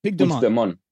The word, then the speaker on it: Puigdemont: Puigdemont. Is that it? Which Dumont. Puigdemont.